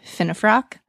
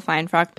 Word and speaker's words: Finefrock: Which, I must correct you now, it's pronounced Finefrock. – Oh, is it Finefrock? – I do tell people Finefrock because they get scared when they're like, how do you spell your name?